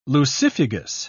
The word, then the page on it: lucifugous: British English